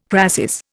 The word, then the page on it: crasis: Indian